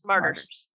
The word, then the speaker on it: martyrs: Martyrs. – Every time I really have to think about it because it's spelled so strangely.